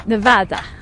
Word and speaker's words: Nevada: Right, but historically speaking, it's a Spanish word that means snow-capped, and if you go to the original Spanish pronunciation, it would be something like Nevada, Nevada.